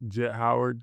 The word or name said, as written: Jett Howard